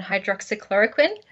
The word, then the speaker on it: hydroxychloroquine: Another big one is the ongoing discussion, somehow ongoing discussion, around hydroxychloroquine.